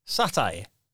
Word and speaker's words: satyr: S-A-T-Y-R. How'd you pronounce that? S-A-T-Y-R. – Satire. – That's what I thought, satire.